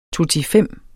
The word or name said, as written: totifem